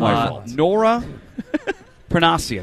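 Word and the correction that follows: Princiotti: How to say that again, Patio? Princiati.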